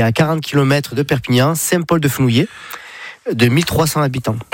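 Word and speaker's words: Saint-Paul-de-Fenouillet: Saint-Paul-de-Fenouillé, c'est comme ça que vous le prononcez. – Ouais, c'est ça.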